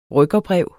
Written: rykkerbrev

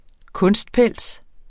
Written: kunstpels

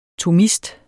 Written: thomist

